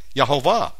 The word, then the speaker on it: Jehovah: And the King James had it right all along, Jehovah. Except, you know, there's no J in Hebrew, so it's actually Y. Yahovah.